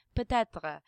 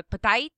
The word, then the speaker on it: peut-être: Like in Parisian French, you'd pronounce something peut-être. But in Quebecois, you'd go peut-être.